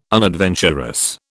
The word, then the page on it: unadventurous: British English